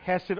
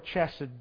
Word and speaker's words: hesed: It's pronounced either hesed or chesed, depending on the English translation.